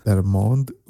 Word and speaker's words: Der Mond: Der Mond y die Sonne.